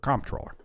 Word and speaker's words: comptroller: to controller the currency, or comptroller. I think it's 50-50 the way people pronounce it.